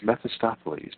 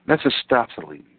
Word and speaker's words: Mephistopheles: Mephistopheles. Now, how in the heck would I ever know how to pronounce that? Mephistopheles.